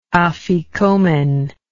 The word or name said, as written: afikomen